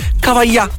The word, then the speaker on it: Cavaglià: Peter Pan Impatto, festa dei giovani di Cavaglià. L'accento sulla A.